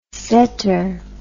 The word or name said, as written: saeter